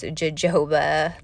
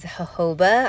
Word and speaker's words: jojoba: If you look at it, it looks like it's pronounced jojoba, but that's not it. It is jojoba, I'm pretty sure. That's how I hear most people say it, so that's what I'm going to go with.